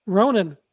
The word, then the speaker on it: Ronin: Ronin rhymes with bonin.